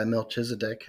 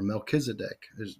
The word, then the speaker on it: Melchizedek: he gets initiated into this order of Melchizedek by Melchizedek himself, or Melchizedek. You hear it pronounced both ways.